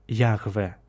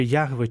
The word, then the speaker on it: Яхве: Воно, мабуть, ми вже християни, скажімо, для нас ця заборона вимовляння ім'я не є такою важливою, зобов'язуючою, мабуть, вимовлялося як Яхве. З інших написів, наприклад, теж з імен, тому що це Боже ім'я іноді входило в склад певних імен, могло вимовлятися чи Яхве, чи Яу, Яо. Різні є гіпотези.